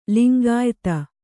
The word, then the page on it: ಲಿಂಗಾಯಿತ: lingāyita